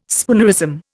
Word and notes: spoonerism: Indian

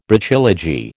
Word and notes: Brachylogy: American English

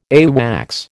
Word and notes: AWACS: American English

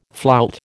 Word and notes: flout: American English

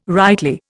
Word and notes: rightly: Indian

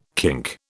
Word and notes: kink: British English

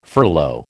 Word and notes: furlough: American English

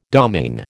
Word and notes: domaine: American English

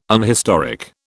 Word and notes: unhistoric: British English

